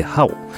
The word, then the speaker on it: HAO: さらに、水戸のハオ、中華料理店ハオですね、 HAO と書いてハオ。こちらはですね、まあ、今ネットでおなじみ、うん、ネットで人気のこれいいじゃんっていうね、万能調味料を作っているあのハオです。